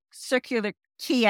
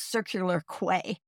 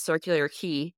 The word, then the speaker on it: Circular Quay: Every time you say Circular Quay, I go, I was mispronouncing it. I was constantly calling it Circular Quay the whole time. So I'm like, that's how you pronounce it.